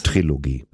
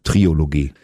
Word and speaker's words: Trilogie: Also wir hören einen Trilogie, dieser drei Songs. – Trilogie. – Was habe ich gesagt? – Trilogie. Ich bin Klugscheißer, schreibt man mit K und der Trilogie lässt man das O weg.